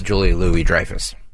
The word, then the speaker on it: Louis-Dreyfus: Secondly, he throws out Julia Louis-Dreyfus. – Julia Louis-Dreyfus. – Okay. It says Lewis here, L-O-U-I-S. – Yeah, it's pronounced.